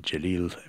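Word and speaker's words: Celil: Well, in addition to the playing of Mr. Jalil, the C is pronounced J in Turkish, and Jalil Refik Kaya.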